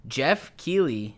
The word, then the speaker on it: Geoff Keighley: Geoff Keighley, is that how you say his name? Yeah.